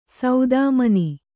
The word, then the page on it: ಸೌದಾಮನಿ: saudāmani